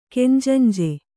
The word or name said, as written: ಕೆಂಜಂಜೆ